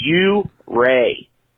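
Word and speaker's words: Ouray: Holman, you are not allowed to come and play in my backyard until you learn how to pronounce the town's name. It's U-Ray. Not U-Ray. U-Ray.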